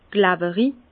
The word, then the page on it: klawerig: Bas-Rhin; Herrlisheim